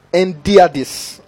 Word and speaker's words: hendiadys: Now, there's something, there's a figure of speech in the Bible known as endiadis. I'm just going to spell it. Or endiadis. It is H-E-N-D-I-A-D-Y-S.